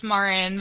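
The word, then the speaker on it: Maren: Hi, it's Marin.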